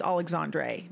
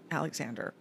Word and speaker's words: Alexandre: I'm pretty sure it's Alexandre, not Alexander, but you never know.